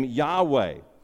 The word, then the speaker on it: Yahweh: And if you transliterate that name, if you look at the Hebrew and just pronounce the Hebrew, you might pronounce that name Yahweh.